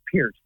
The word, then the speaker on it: Peart: Well, the real fans would tell you it's Peart. And he says it is Peart, so I think you have to respect how the man pronounces himself.